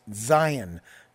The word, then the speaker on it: Zayin: We're ready to talk about the seventh letter of the Hebrew alphabet, which is pronounced Zayin. Zayin. Z-A-Y-I-N. You can see that in your chart as well. Zayin is, once again, a two-syllable word. So we put the emphasis on the first syllable to get us to sound out the Z sound.